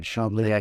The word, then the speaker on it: Chambly: By the way, I'm impressed by your uh, pronunciation, uh, Jaakko. You got it right. Chambly, I come.